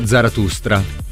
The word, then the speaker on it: Zarathustra: Così parlò Zarathustra. Ma è riuscito a pronunciarlo personalmente. – Sì, attenti a non mettere l'R per me, dopo la prima T.